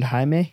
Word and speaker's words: Jaime: So two are named Jaime? – Jaime, yeah. It's spelled J-A-I-M-E.